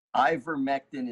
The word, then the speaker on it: ivermectin: Ivermectin is I-V-E-R-M-E-C-T-I-N, ivermectin.